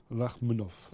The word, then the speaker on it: Rakhmonov: Rakhmonov.